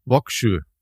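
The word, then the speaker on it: Vågsøy: Etwa in Operation Archery, äh, was ein Angriff auf eine deutsche Garnison im äh, norwegischen, ich, ich weiß jetzt nicht, wie man es ausspricht, Vöxøy? Wie ja, äh, schreibt man's? Mit einem mit einem Kringel A oben. V Kringel A G S O Y. – Wokschö. – Wokschö. Wokschö.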